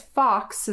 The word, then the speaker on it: Fochs: So a lot of information can be found there, as well as the book Minnesota's Lost Towns, Northern Edition by Rhonda, I guess Fox is how I pronounce this last name, mm-hmm. F-O-C-H-S, if you want to look this up.